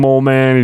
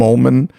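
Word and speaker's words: Moleman: I love that it is always, I think, always pronounced Mole Man and not mm-hmm. Molman. – Mm-hmm. – So it's like Hans Molman, you know, but like Hans Mole Man.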